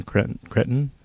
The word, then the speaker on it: Cretton: And then a film I did get to see at South by Southwest is Short Term 12, Destin Cretton. Is that mm-hmm. how you pronounce it? – Yes, correct.